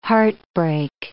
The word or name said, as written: heartbreak